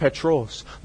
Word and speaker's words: Petros: See, friends, in the Greek, that word Peter is pronounced Petros, the small pebble, the small movable pebble.